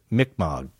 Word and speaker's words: Mi'kmaq: No, but I've heard it generally pronounced Mi'kmaq.